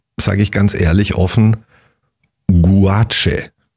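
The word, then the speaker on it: gouache: G-O-A-C-H-E. – Guache. – Guache. – Guache. – So spricht man es aus.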